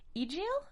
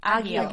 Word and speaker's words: Egil: Um, but we have a new another new character Egil or Agil? Agil. – Agil. Agil. Okay, yeah.